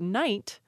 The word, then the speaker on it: knight: Now let's talk about another word that has two things preserved in it, and this would be the word knight, K-N-I-G-H-T. – Oh, the silent K. It's the bane of first graders everywhere. – And that silent K was actually pronounced. That word was pronounced knicht, which is just really fun to say. So you had that first, the initial K, and then the G-H representing a H sound, so knicht, and the initial G in gnat was actually pronounced at one point, so it was a gnat.